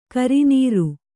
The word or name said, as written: ಕರಿನೀರು